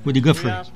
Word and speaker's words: Woody Guthrie: Como é que fala isso? – Woody Guthrie.